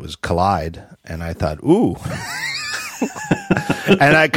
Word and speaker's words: Kolide: Anyway, Collide, KOLIDE. They are a software as a service startup. And oh, here it is in the notes, pronounced Collide.